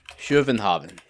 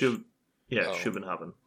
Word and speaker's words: Copenhagen: Is this Copenhagen? – Schuvenhaven. – Ugh. Is that how it's said? – In Danish. – Schuvenhaven.